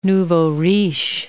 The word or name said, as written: nouveau riche